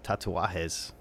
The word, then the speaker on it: Tatuajes: Well, you released your own version of Despacito on your 2018 album, Tatuajes. Did I say that right? – Yes.